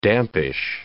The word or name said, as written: dampish